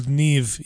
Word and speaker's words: Niamh: We have a beautiful Irish name called Niamh. You will have heard this before. It is spelled N-I-M-H.